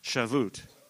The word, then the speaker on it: Shavuot: The second feast, which was a mandatory feast, was what was called first fruits, or Hebrews call it, some you pronounce it something like Shavuot, which means Pentecost.